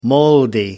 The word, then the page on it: moldy: British English